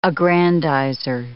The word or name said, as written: aggrandizer